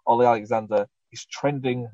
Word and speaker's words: Alexander: Olly, how do you say his last name? Alexandra? – Alexander. – Alexander, Olly Alexander.